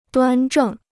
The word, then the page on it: 端正: duān zhèng